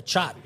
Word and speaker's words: chaat: Yeah, the chaat.